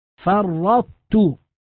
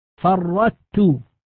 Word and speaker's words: فرطت: ومن ذلك القسم ما نذكره الآن في الإدغام في المثلين والمتقاربين والمتجانسين، فمثلا كلمة فرطت، نلاحظ أنها عند النطق بها لابد أن نبقي صفة الطاء وهي الاستعلاء، فننطق بتاء مشددة ويبقى أثر الاستعلاء في الطاء، فرطت، هذا إدغام ناقص، لا يجوز أن نقول فرطت هكذا بتاء مشددة، هذا النطق خطأ.